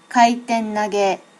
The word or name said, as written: kaitennage